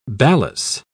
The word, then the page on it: balas: British English